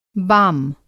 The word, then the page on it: बाम: Hindi